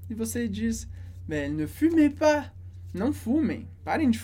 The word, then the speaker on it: fumez: Ne fumez pas. A gente tem o verbo fumer. Exatamente a mesma pronúncia de fumer e z no final. De fumer e r, que é fumar.